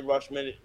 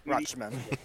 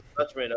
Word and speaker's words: Rutschman: Ellie Rutschman. How do you say the name? – Rutschman. – Rutschman.